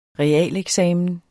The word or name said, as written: realeksamen